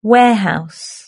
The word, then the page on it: warehouse: British English